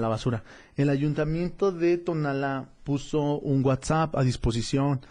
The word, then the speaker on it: Tonalá: Tonalá con acento en la última A, tal cual como se escribe,